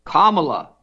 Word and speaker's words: Kamala: Kamala, and as in Camelot, Kamala.